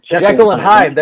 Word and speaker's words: Jekyll and Hyde: Well, as you look at that Cincinnati team, I mean, it's been kind of a heckle and jide type of team. Am I saying that right? I feel like I'm saying that wrong. – Jekyll and Hyde.